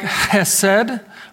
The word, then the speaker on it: chesed: It's pronounced something like chesed. I think I did it somewhat justice, but this word is a word often you find these Hebrew words that can't be summed up in one English word.